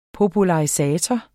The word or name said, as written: popularisator